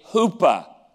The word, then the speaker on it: chuppah: He would build what they called a huppah, and it's spelled C-H-U-P-P-A-H.